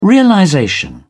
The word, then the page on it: realization: British English